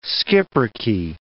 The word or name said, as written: schipperke